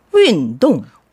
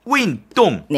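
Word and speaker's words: 윈동 (运动): 1번 윈동. – 윈동. – 네, 그렇죠. 힘차게 사성사성 윈동. – 윈동. 운동할 때는 정말 힘차게 힘줘서야 될 거 아니에요. – 네. – 네, 사성사성입니다.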